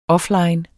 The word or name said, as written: offline